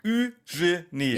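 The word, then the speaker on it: Eugénie: Wo ist der denn schrecklich? Eugenie. Das sind 3 Silben. Eugenie.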